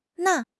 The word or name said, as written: Na1